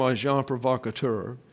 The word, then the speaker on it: agent provocateur: And so I'm telling you the way it's pronounced in all the dictionaries. Agent provocateur. And even when it's plural, it's provocateur.